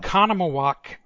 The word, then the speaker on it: Oconomowoc: Oconomowoc.